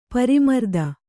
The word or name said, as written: ಪರಿಮರ್ದನ